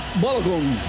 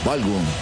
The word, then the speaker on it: Balogun: Balogun. – Balogun, muy bien.